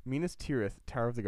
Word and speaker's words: Minas Tirith: Minas Arnor was renamed Minas Tirithil Tower. Is that, I said that wrong? – Minas Tirith. – Tirith. I'm trying my best.